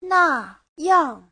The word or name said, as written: na4yang4